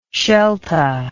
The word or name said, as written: shelta